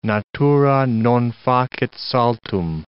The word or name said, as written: natura non facit saltum